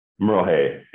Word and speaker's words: Merle Hay: And I'm sorry, the pronunciation, is it Merlehead Mall? – Merlehay, H-A-Y, Merlehay.